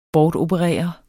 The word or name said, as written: bortoperere